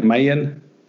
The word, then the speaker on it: Maya: We pronounce M-A-Y-A here in the States, Maya. Is it actually pronounced Maya over there? Because I don't think I knew that. – Yeah, I would say Maya.